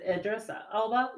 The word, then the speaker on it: Idris Elba: Is it Idris Elba? – Yes.